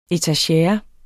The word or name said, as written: etagere